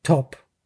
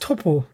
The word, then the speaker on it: Toppo: Top. Und dann habe ich mir gedacht, aber der Typ heißt Toppo, nicht Top. Wir sagen in, in Deutschland, da spricht man Toppo nicht Top, so wie in Amerika sagt er tatsächlich nur top?